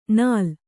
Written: ನಾಲ್

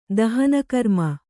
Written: ದಾಹನಕರ್ಮ

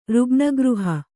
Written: ರುಗ್ಣಗೃಹ

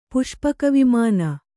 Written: ಪುಷ್ಪಕವಿಮಾನ

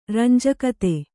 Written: ರಂಜಕತೆ

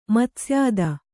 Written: ಮತ್ಸ್ಯಾದ

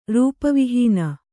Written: ರೂಪವಿಹೀನೆ

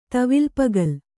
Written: ತವಿಲ್ಪಗಲ್

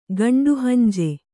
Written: ಗಂಡುಹಂಜೆ

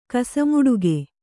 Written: ಕಸಮುಡುಗೆ

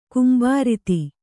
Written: ಗುಂಬಾರತಿ

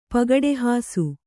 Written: ಪಗಡೆಹಾಸು

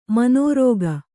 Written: ಮನೋರೋಗ

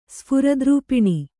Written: ಸ್ಫುರದ್ರೂಪಿಣಿ

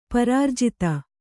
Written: ಪರಾರ್ಜಿತ

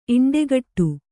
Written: ಇಂಡೆಗಟ್ಟು

